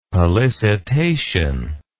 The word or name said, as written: pollicitation